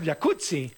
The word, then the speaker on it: jacuzzi: Entonces deberíamos pronunciarlo jacuzzi. – Exacto. – Como pizza. Como rampazzo, sí. Como Claudia Rampazzo.